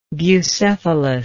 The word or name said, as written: bucephalus